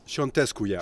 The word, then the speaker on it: Ciontescu: Ciontescu, yeah.